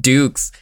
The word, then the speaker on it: Dux: Ducks this whole most time. – Consecutive knock- oh, well, it's all bullshit anyway. – Fuck me. Fuck me, his name is pronounced Dukes.